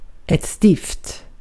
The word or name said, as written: lead